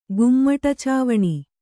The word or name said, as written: ಗುಮ್ಮಟಚಾವಣಿ